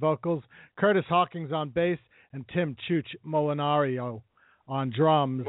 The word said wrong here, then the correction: Molinaro: Molinaro. – Molinaro. – Yeah, I mispronounced that. I've got a lot of grief for that one. – Oh, okay. Because I'm reading it like an Italian would read it the way it's spelled.